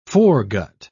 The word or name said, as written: foregut